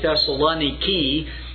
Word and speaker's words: Thessaloniki: The title slide that you've been seeing this morning is of the harbor there at modern-day Thessaloniki, is the way it's pronounced, and with a K instead of a C, so it ends with K-I in their language today.